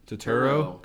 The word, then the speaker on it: Turturro: Is it John Turturro? – Turturro.